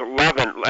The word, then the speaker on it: Leavenworth: It just went to the familiar, and I didn't, and you pronounced it correctly. It was my ears that... – Right, yeah, it was Leavenworth.